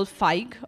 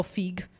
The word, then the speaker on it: Feig: Now, this cult classic has been remade in 2016 by Paul Feig, or Feig. I think he's pronounced his name Feig.